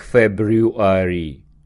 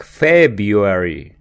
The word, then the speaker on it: February: Обратите внимание, слово February читаем не как February, а как February. Первая буква R не читается.